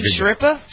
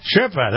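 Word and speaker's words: Schirripa: Scipiro, how do you pronounce this? I remember having him on the air once with me, and he was a... – You mean Steve Shripa? – Shripa, that's it, yeah.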